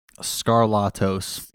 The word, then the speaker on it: Skarlatos: What is the exact pronunciation of the last name? – Skarlatos. – Yeah, Skarlatos.